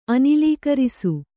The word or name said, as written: ಅನಿಲೀಕರಿಸು